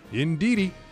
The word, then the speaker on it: Ndidi: There's a player on Leicester spelled N-D-I-D-I. Do you know how to pronounce that? – Ndidi.